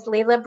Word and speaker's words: Leela: I'm your host, and I'm really, really excited to introduce you to my new friend, Miss Leela Brown. It's Leela with a long E.